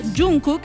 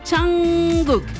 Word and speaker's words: Jungkook: Y acá vamos a empezar con que su nombre no se pronuncia Jungkook ni Jungkook. Su nombre se pronuncia Jungkook. Esa es la verdadera pronunciación del nombre de Jungkook.